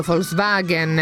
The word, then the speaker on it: Volkswagen: Cosa ne direbbe Herbie, il maggiolino tutto matto di tutto questo scandalo Dieselgate, lui che è di quel marchio Volkswagen, e dobbiamo stare attenti a pronunciarlo correttamente con la F davanti perché gli ascoltatori ce lo segnalano puntualmente.